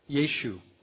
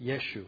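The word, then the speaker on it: Yeshu (ישו): How do you pronounce yud shin vav? Does anybody know? Yeshu. That's how you pronounce it, Yeshu.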